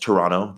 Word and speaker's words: Toronto: Lots of my tribe seems like hails from Toronto, Canada and so uh, or Toronto, Ontario, Canada, or for the locals, it's pronounced Toronto. That last T is kind of silent.